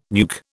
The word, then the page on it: nuke: British English